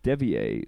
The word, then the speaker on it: Devie: Um, let's talk about the horse you just mentioned as well, Meri Devie. So Devi, yes. Devi, Meri Devie, yes. Uh, my French pronunciation.